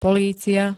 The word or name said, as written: polícia